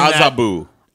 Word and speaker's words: Azabu: Excuse me, TJ. It's Azabu.